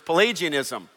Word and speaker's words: Pelagianism: It's time for another heresy, and this is the one, you pronounce it this way, I was asked before service, Pelagianism.